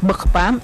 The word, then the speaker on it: Bokpam: Pero su pronunciación es la que hace la diferencia. Se pronuncia Ugh. Entonces, por eso es Bokpam.